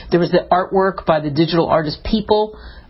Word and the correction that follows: Beeple: or Beeple is how you say it really, that sold for $69 million at Christie's, and that got attention.